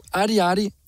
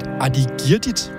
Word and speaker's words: Ardiardi: Så her får du Ardiardi og Barcelona med Stå op, gå ned. Jeg troede i starten, at det blev udtalt Ardiardit, men så blev jeg lige rettet af en kære ven.